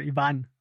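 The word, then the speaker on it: Iván: Ivan. It's got an accent on the A, but I mean, you know.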